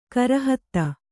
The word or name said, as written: ಖುರಾಹತಿ